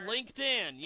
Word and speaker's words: LinkedIn: I'm on, I can't ever say it right, Lindekin, I'm on there. – Oh, LinkedIn, yes, you're on LinkedIn.